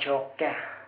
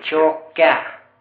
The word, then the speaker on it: Tjocka: Brösarp och tjocka. – Hur uttalar du det? – Tjocka. – En till. – Tjocka. – Jaha. – K-ljud i första stavelsen och K i andra.